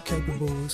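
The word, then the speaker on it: Khaipable: That's Capable, spelled with a K-H-A-I-P-A-B-L-E.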